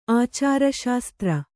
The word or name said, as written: ಆಚಾರಶಾಸ್ತ್ರ